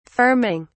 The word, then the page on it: firming: British English